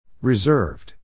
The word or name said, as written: reserved